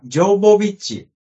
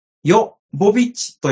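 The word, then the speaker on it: Jovovich: 名前の表記はジョヴォヴィッチですが、本人の母国キーウのウクライナ語の発音に近い、ヨ・ボビッチと呼んでね、と発言していますので、ここでは、頑張ってヨ・ボビッチと発音したいと思います。 – うん、ちなみにジョヴォヴィッチというのは英語読みだそうです。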